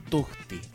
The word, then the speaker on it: tucti: Conejo se dice tuxti o tuxti, así se pronuncia, se escribe T-U-C-T-I, pero se pronuncia tuxti.